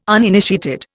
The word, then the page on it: uninitiated: Indian